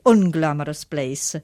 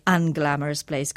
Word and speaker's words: unglamorous: Qui notate che in inglese si dice un, no? UN, lo pronunciano come se fosse scritto con una A, un irlandese c'è una via di mezzo che è molto più simile alla O, quindi lei dice unglamorous place anziché unglamorous place come direbbe un inglese o un americano.